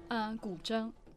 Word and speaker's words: guzheng: pronounce the name of your instrument? – Guzheng. – What she said. It looks like it's spelled in English G-U-Z-H-E-N-G. And the name of the piece again was? – Is Fragrance of Jasmine's Blossom.